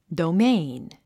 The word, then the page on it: domain: douméin; ドゥメイン